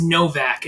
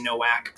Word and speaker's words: Nowak: It's Nowak. It sounds like it's with a V. – Nowak. – It's okay. Spelled with a W. I'm from Polish descent, actually, and they pronounce their letters kind of weird sometimes.